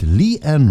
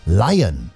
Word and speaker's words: Lian: Jetzt gibt es schöne tanzbare Disco-Mucke mit Lee Ann Ross und Fantasy, ja? Nicht Lion. Nein, nein, die Dame möchte wirklich gerne Lee Ann ausgesprochen werden, obwohl da L-I-A-N steht und man könnte denken, na Lion war oder so.